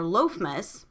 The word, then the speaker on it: Lammas: Like, one of the first things that come up was the Wikipedia link for that, and it was described as a Christian holiday pronounced Lammas or Lofmas, also known as Lofmas Day.